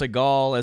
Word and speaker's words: Sehgal: We were joking earlier that your last name is spelled S-E-H-G-A-L, but it's pronounced Seghal, as in Steven Seghal.